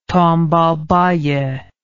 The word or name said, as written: Tombalbaye